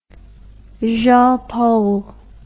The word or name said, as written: jean paul